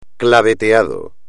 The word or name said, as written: claveteado